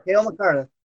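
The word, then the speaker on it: Cale Makar: I protected Kyle McCarr. Excuse me. – Kale McCarr.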